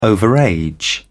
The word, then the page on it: overage: British English